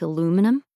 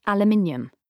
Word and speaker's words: aluminium: "Like aluminum?" – "You mean aluminium?" – "Oh, there it is." He cupped his ears in pleasure. – "That extra syllable, aluminium, it undoes me."